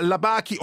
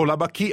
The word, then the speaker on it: Labaki: Nadine Labaki, o Labaki a seconda che la si pronunci alla francese, il film è E ora dove andiamo?